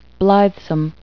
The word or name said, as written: blithesome